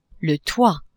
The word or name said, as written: toit